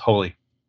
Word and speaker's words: Hole: In Austria, it's pronounced "holy," Harry Holy, mm.